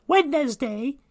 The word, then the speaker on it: Wednesday: You know how they spell it? You know, it's kind of different. You know, you think you'd say Wednesday. Wednesday is how it should be pronounced, but you know, the English language is a little different. And so I guess people say Wednesday. They kind of just skip that whole D in there.